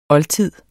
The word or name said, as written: oldtid